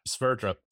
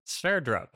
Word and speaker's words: Sverdrup: Sverdrup. – Sverdrup.